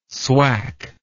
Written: swak

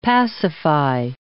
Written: pacify

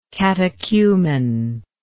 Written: catechumenism

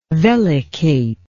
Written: vellicate

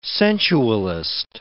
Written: sensualist

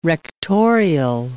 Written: rectorial

